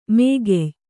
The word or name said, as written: ಮೂಗೆಯ್